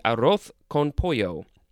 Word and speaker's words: arroz con pollo: in Spanish, the S sounds are TH sounds. So, in that accent, it would be arroz con pollo. It is spelled A-R-R-O-Z, next word, C-O-N, Next word, P-O-L-L-O.